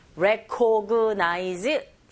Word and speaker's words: recognize: Recognize. 자, 이렇게 발음하시면 안 되고 강세를 주셔야 되죠. 정확한 위치에 re, 이 부분 첫 번째 있습니다.